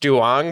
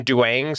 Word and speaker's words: Duong: No, I totally know it's legitimately pronounced Duong, but, like, the memes have always been Duang, so I always say Duang, like, intentionally.